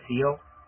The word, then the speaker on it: SEO: Ahí como me pronuncio mucho, todos los que me han hablado de esto, el este, el SEO, ¿es cómo? – SEO, SEO. – Ajá, sí, esos son los que siempre pronuncian.